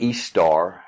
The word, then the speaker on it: Ishtar: Ishtar, I-S-H-T-A-R, H is silent.